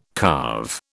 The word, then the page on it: carve: British English